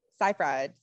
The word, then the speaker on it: Seyfried: Amanda, how do you say her last name? Siegfried? – Seyfried. – Seyfried. Yes.